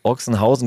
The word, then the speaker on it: Ochsenhausen: Am Samstag haben die Jungs auch noch den deutschen Meister aus Ochsenhausen geschlagen. Weil du dich in der Ecke auskennst, wie spricht man es richtig aus? – Ochsenhause.